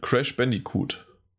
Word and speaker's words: Crash Bandicoot: Bei mir ist es ähm, den Namen, den ich bisher immer noch nicht richtig aussprechen kann: Crash Bandicoot.